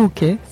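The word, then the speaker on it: Åke: Celle-ci s'appelle toujours OK, ça s'écrit A-K-E et se trouve rue Marie-Louise, à deux pas de l'ancienne, toujours dans le 10e arrondissement.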